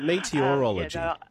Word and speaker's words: meteorology: Is it meteorology? Meteorology. – That's right. It is like meteor.